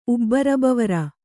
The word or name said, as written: ಉಬ್ಬರಬವರ